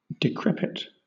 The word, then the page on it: decrepit: British English